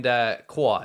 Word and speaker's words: Guo: And Guo, it's spelled, but it's pronounced Guo.